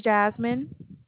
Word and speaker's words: Jasmine: My name is Jasmine. – Would you spell it J-A-S? – M-I-N-E.